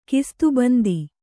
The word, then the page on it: ಖಿಸ್ತುಬಂದಿ: khistubandi